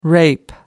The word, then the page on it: rape: American English